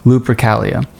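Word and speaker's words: Lupercalia: Anyways, so the leprosias, or lupricaias, sorry, lupricaias and lupricalia, that's how you pronounce it, lupricalia.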